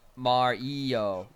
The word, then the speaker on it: Mario: Rusty asks, I've noticed recently that the British pronounce Mario with a long A. Mario. – Mario. – Mario. – Kind of like Cheerio.